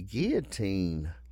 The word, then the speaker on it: guillotine: He was found guilty of murdering all but three of the victims tied to him and sentenced to death by guillotine. – Guillotine. – Oh. – You don't pronounce the L's in guillotine. – Guillotine. – Excuse me, guillotine.